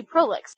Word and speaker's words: prolix: No, it's Latin. I mean, it's based on a Latin root, so it should be prolix.